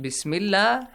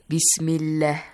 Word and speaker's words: Bismillah: So, this one, Bismillah, has a kasra before, yeah? – Yes. – Read it. Read it once. – Bismillah.